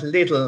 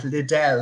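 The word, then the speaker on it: Lidl: It reminds me, like, then some people called it Little, and I knew a girl who used to call it Liddell. It was like a very posh pronunciation.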